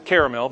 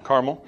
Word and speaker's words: Carmel: Now, some people pronounce it caramel, but I pronounce it Carmel.